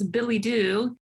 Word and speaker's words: billet-doux: It's billidoux. And it's spelled B-I-L-L-E-T-D-O-U-X. And it simply means a love letter. So I suppose I wish that my husband would write me a billy-doo every once in a while,